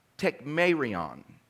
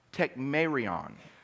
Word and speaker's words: tekmerion: But the term infallible proofs is actually one word in the Greek, and it's pronounced tekmerion, and it's spelled like this, T-E-K-M-E-R-I-O-N, tekmerion. It's Greek, T-E-K-M-E-R-I-O-N, tekmerion, tekmerion.